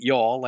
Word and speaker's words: Youghal: It's spelled Y-O-U-G-H-A-L, but they say it Youghal like they do in the South, Youghal.